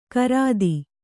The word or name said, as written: ಕಾರ್ತಿ